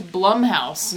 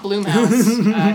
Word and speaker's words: Blumhouse: It is, in fact, Blumhouse, not Bloomhouse. His name is Jason Blum, and we've been mispronouncing it this whole time.